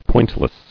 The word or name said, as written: Pointlessly